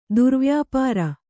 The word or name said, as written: ದುರ್ವ್ಯಾಪಾರ